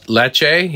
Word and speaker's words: Lecce: Is it Lecce? – Lecce, yeah.